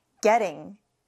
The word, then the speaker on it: getting: 그럼 발음이 getting. getting. 이렇게 T 발음을 부드럽게 해주셔야 합니다.